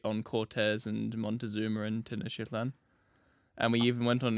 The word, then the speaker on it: Tenochtitlan: Yeah, taking the city of Tenochtitlan. I fucking have it written pronunciation here. I'm still going to mispronounce it. I first read it, and in my head, I was pronouncing it as like Tectoctitlan.